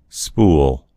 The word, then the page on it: spool: American English